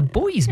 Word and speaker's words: Buoys: Because a mate of mine reckons when I showed him the title of the band, it says The Buoys, and I said, no, it's The Boys, Mr. It is spelt like the flotation device, yes.